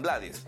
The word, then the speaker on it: Blades: él acepta que le digan Blades, pero su apellido realmente se debe pronunciar como Blades.